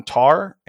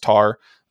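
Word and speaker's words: Tár: keep your eye on Tar and Triangle of Sadness. I think it's pronounced Tar.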